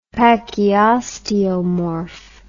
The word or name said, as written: pachyosteomorph